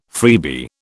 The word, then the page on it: freebie: British English